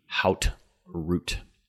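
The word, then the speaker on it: Haute Route: It's pronounced oat and root, but the American pronunciation would be hout root.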